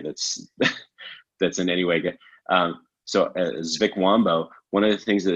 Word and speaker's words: Zvikwambo: Yeah, Zvikwambo. Z-V-I-K-W-A-M-B-O. Zvikwambo.